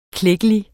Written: klækkelig

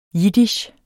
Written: jiddisch

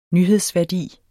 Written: nyhedsværdi

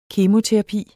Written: kemoterapi

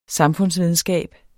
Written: SAMFUNDSVIDENSKAB

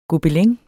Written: gobelin

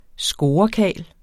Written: scorekarl

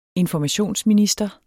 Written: informationsminister

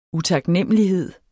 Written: utaknemmelighed

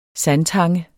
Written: sandtange

